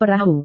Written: perahu

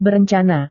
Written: berencana

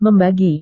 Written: membagi

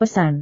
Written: pesan

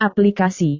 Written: aplikasi

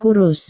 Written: kurus

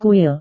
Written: kuil

